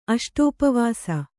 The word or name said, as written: ಅಷ್ಟೋಪವಾಸಿ